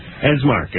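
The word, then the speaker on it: von Esmarch: Nikki Cox and Nick Von Es. No, wait a minute. I don't want to mispronounce it again. Esmark are here tonight.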